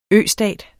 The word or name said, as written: østat